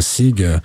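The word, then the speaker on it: Sigh: Je sais pas comment on prononce, si c'est Sig, mais je pense qu'on dit Sai comme un soupir.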